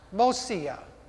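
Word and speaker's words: mashiach: That word for anoint in Hebrew is pronounced Mosia. You hear the same thing? Messiah, Mosia. We need,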